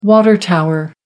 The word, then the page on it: water tower: American English